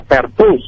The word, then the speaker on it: Pertus: Pertus, no lleva tilde, apellido muy aquí de la región de la Costa Atlántica, Pertus Molina.